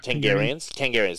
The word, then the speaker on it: Targaryens: Is that how you say it? Targaryens? – Yeah. – Targaryens.